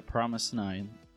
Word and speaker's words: FROMIS_9: And then you also were looking at, is it pronounced Promise 9? – Yeah, Promise 9. They spell it FROMIS_9.